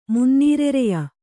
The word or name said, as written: ಮುನ್ನೀರೆರೆಯ